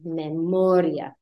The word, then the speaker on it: memoria: IA endings, you're only going to emphasize it if your I is accented. Otherwise, it needs to fall on the syllable beforehand. Memoria, historia, gastronomia. There I pronounce the, or there I emphasize the I.